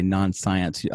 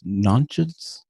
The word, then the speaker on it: Nonsense: And my American pronunciation looks at the word and wants to say non-science. Off record, you had said that the British pronunciation was nonsense.